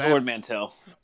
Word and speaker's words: Ord Mantell: So, they go to Ord Montell, find Cid. – It's Ord Mantell. – Ord Mantell.